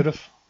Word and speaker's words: Dourif: I don't know how to pronounce his fucking name. Dourif. Dourif. Oh, that's weird. Like Jury.